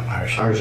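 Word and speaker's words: Arjhan: J-H-A-N. – What is it? How do you Arshan. – Say it? – Arshan. – Arshan. Mm-hmm. – Arshan.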